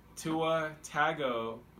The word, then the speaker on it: Tua Tagovailoa: Whether Jalen Hurts going into halftime and getting pulled for a freshman quarterback, let me pronounce this real correct because I was practicing, Tua Tagovailoa. – Tua Tagovailoa? – Yeah, you gotta pronounce it syllable by syllable.